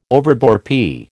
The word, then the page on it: overbore: American English